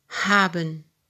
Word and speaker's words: haben: Acuérdate que la H se pronuncia no como en el español. Se pronuncia ha, haben.